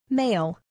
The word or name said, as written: male